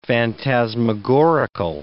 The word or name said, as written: phantasmagorical